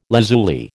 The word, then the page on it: lazuli: American English